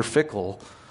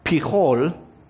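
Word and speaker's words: Phicol: In the Hebrew, it's pronounced Pichol, but that sounds like pee-hole. And so I'm going to Americanize that on purpose and just go with the hard velar stop. I'm going to go with Picol. That is a mispronunciation on purpose.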